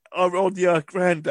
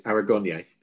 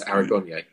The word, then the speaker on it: Anagonye: She keeps getting his last name wrong, doesn't she? – Yes. – And then she calls him Aronia Grande. – Right. She tries about six different times. Each time she says something, he says Aragonia. – Yeah. – And she says, "No, well, why don't you say it like you just did?" He says, "I did. It's my name.